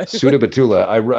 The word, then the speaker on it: Sudabattula: Sudabattula.